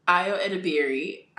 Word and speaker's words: Ayo Edebiri: Ayo Edebiri, I think that's how you say her name.